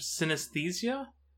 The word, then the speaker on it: synesthesia: Mirror touch synesthesia? – Okay.